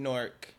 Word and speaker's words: Newark: I like how that's one syllable, by the way. Newark. – Yeah, it's Newark. – I'm used to Newark. – Like most people like Newark, but it's Newark. Like, no, it's Newark. Newark.